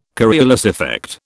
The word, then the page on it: Coriolis effect: British English